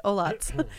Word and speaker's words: Olhats: I tried Olhats. The H is silent, everybody, including me. – Like most of the French H.